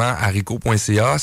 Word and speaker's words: Arico: Alors, premièrement, haricot.ca, ça s'écrit A-R-I-C-O.ca.